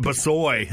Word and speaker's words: Brossoit: Laurent Brossoit. That's how he pronounces it in BC where he's from.